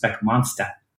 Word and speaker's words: Specmonstah: When I was telling him about this, he was like, well, we are in Boston, so I think it's pronounced Specmonstah, using the Boston accent. – Right, I see. Yeah. And so that's to help you pronounce it properly, the A-H at the end.